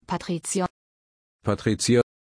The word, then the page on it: patrizio: German